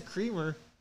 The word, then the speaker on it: Kremer: That is Creamer. – What's Creamer? – Spell it. – K-R-E-M-E-R. – K-R-E-M-E-R.